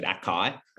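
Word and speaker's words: açaí: So first the thing, it's spelled A-C-A-I, right? And everyone calls it acai. It's actually, if there's a little hyphen under the C and above the I, so it's pronounced acai.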